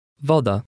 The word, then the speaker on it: Woda: The letter W is almost always pronounced like a V, as in the English word very. Read number 1. – Woda.